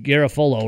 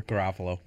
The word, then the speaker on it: Garofalo: What I said from NFL Network, Mike Garafolo or whatever. Garofalo. I said it right the first time. Garofalo.